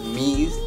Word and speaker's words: Meez: It's Meez, M-E-E-Z, and then it's not dead.